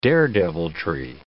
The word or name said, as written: daredeviltry